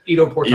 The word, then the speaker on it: Ido Portal: Ido Portal.